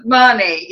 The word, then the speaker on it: Mahoney: Mahoney, yeah? – Yes.